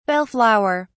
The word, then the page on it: bell flower: British English